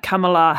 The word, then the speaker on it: Kamala: Kamala Harris's? – Harris, yes, the vice president.